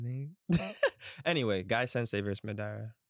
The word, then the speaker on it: Madara: Can you pronounce his name properly, please? No, it's Madara. – Madara. – That's Madara, better.